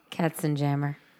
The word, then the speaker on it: Katzenjammer: Oh. I was Katz. How do you pronounce it? – Katzenjammer.